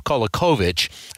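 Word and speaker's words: Kolaković: One of them is Father Tomislav Kolakovich. First of all, did I pronounce his name right? And second of all, can you tell us a little more about him? – Yeah, you got it.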